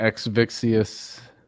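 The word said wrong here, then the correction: Exivious: Exivius, there you go. I added an extra syllable in there.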